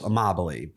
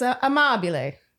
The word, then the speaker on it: Amabile: Am I saying that right? – Amabile? You're close enough. You're close. Amabile. But like you said it pretty well. – Okay. – Yeah. – I feel like it's Amabile.